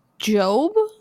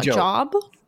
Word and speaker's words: Job: So just before, for clarification, that's pronounced Job, not Job.